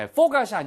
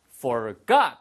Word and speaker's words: forgot: 발음 주의하세요. 예, forgot이 아니고요. forgot 하고 뒤에 액센트가 있습니다.